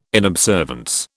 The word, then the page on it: inobservance: British English